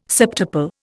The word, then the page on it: septuple: Indian